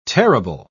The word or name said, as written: terrible